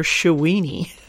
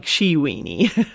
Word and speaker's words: Shawini: And then, let's see, we went for call, here's another one, we went for calling it her Shawini with a soft E, pronounced more like how Wayne and Garth would say Shawing, not pronounced like Sheweeny.